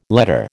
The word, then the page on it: letter: American English